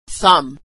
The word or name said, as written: thumb